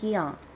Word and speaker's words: Gion: My name is Ayumi Gion. – I'm sorry. Could you spell that, please? – G-I-O-N.